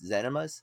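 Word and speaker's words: Ximen's: in town called uh I believe it's pronounced Zenimas, um, but it's like X I M E N apostrophe S I think.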